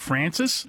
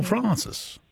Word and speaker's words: Francis: Is it, you know, do you flatten it out, Francis, like, or Francis? – Yeah. And the Francis pronunciation is older, so that's got the pedigree. – Yes. – And whereas Francis was, the R sound was complained about in the 1800s.